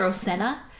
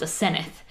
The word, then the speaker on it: Asenath: Well, they call her Osenna, but we have a friend whose name is... – spelled the same way. – Spelled the same way, but it's pronounced Asenath.